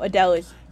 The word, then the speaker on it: Adela: Like, maybe even kill, like, Adela. Am I mispronouncing her name? – No, Adela's.